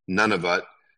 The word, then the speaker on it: Nunavut: And then if you go around, if you go on top of Manitoba, you see it's pronounced Nunavut, Nunavut,